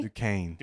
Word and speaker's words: Duquesne: Duquesne? How do you say it? Duquesne? – Duquesne. – Duquesne. Okay, I knew it was close.